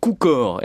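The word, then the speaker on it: cukor: Sucre se dit pas sucker, sugar ou sucre, non ça se dit kukor.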